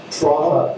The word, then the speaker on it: Trottle: Trottle. It's spelled T-R-O-T-T-L.